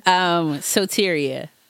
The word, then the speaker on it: Soteria: Soteria is my name. And I always like to say it's Greek. It ain't ghetto for people who don't know Greek.